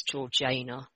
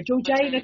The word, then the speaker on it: Georgiana: So I am I knew you were going to pronounce it wrong. I was looking at the notes and I was like, apparently it's Georgiana. – Yeah. – Okay, sorry, Georgiana Cavendish.